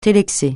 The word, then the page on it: télexer: francais